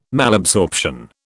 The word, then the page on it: Malabsorption: British English